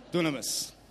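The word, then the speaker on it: dunamis (δύναμις): Any Greeks in the room? I'm sure I'm not saying that right. Oh, there's no Greeks. Yeah, that's exactly how you say it, like that. Dunamis.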